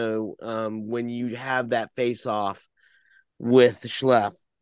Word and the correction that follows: Shiflet: with Schleff, um, I I'm, mispronouncing that name. – Shiflet. Shiflet. Uh, thank you very much.